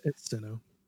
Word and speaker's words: Sinnoh: It's Sinnoh.